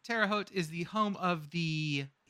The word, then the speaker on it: Terre Haute: It's Terre Haute.